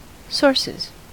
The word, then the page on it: sources: American English